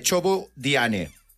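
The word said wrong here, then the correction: Ceebu jën: No sé si lo he pronunciado bien. Chobo diane. – No lo has pronunciado. – Me lo ha dicho un... – No lo que querías. – ¿Cómo es la pronunciación, Tav? – Chebujen. – Ah, ¿ves? Bueno, casi.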